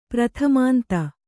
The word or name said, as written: ಪ್ರಥಮಾಂತ